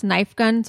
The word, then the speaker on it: Nifegun: It's pronounced Nifegun, so I've been told by one of their fans, well, Banana Cakes. It's spelled N-I-F-E Gun Official, and that's their Instagram.